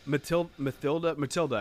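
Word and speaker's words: Mathilda May: Matilda, I guess I shouldn't pronounce the H.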